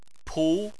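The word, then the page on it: pu: tone 2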